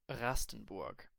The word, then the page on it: Rastenburg: standard german